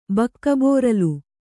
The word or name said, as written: ಬಕ್ಕಬೋರಲು